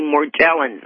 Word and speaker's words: Morgellons: Actually, I hear it's pronounced with a hard G, Morgellons.